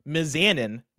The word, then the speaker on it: Mizanin: The Miz's last name is pronounced Mizanin, not Mizanin.